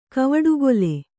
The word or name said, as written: ಕವಡುಗೊಲೆ